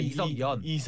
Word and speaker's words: 이석연: 저기 강찬호 위원이 흡족하면 그 저기 이석현 위원장은요 지금 진보 진영의 발음을 잘하셔야 돼. 이석현 평론가가 있으니까 이석연.